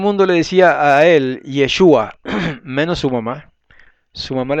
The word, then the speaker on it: Yeshua: Es Yeshua con acento en la U, es Yahweh con acento en la E.